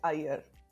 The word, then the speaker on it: Iyer: And my last name is pronounced Iyer.